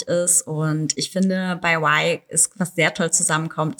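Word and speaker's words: Wye: Bei Y, W-Y-E geschrieben, aber Y ausgesprochen,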